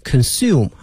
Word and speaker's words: consume: 所以同学们记住这个词 consume 啊，消费啊，它的发音是 u 这个音，而变成名词呢是 consumption 啊。